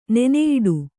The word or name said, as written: ನೆನೆಯಿಡು